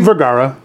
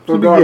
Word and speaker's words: Vergara: I am here with John Vergara. Did I say that right? Did I say that correctly? – Vergara. – Vergara. – Yeah. – Vergara. – There you go.